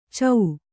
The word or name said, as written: ಛ